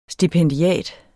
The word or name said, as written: stipendiat